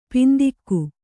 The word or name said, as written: ಪಿಂತೆಗೆ